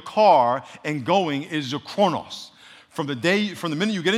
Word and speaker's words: chronos: Everyone say chronos. Say chronos. it's spelled C-H-R-O-N-O-S, but it's really, the O's are, Omicron in Greek is or, like offer, and kairos.